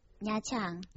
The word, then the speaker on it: Nha Trang: Nha Trang? – Nha Trang.